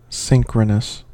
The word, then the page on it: synchronous: American English